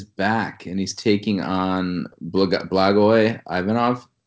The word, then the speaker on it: Blagoy Ivanov: Blagoy Ivanov. I have a little bit of expertise as to how to pronounce Russian names, if nothing else, because I have a Russian heritage and speak the language. So I'm gonna say that with authority. Blagoy Ivanov.